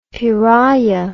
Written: piraya

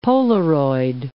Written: Polaroid

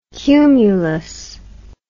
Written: cumulous

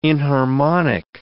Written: inharmonic

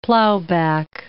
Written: plowback

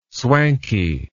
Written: swanky

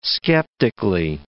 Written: skeptically

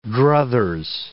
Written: druthers

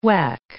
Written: whack

